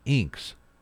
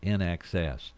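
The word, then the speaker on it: INXS: People were saying Inks. Who's this band? Is it Inks? No, finally, the record promotion people said it's NXS.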